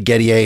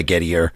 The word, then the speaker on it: Gettier: It is Gettier. It's normally Gettier. It's a French name, but he was an American. He went by Gettier.